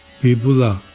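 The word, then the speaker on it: vipula: 在大藏经中，这个字多用在毗弗罗，或是写作毗弗罗这个词中，它的梵语读作 Bibula，然后藏文是 Kebam Yangba。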